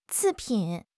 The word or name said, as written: 次品